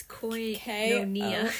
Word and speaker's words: koinonia: So, if you guys didn't know, the Greek word for fellowship that we see often is koinonia. – It's hard to spell and pronounce, by the way. – It's koinonia. – K-O-I-N-O-N-I-A.